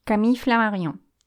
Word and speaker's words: Flammarion: Camille Flammarion.